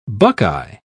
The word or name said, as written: buckeye